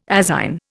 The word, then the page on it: azine: Indian